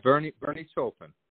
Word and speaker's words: Bernie Taupin: That's right, Bernie Taupin.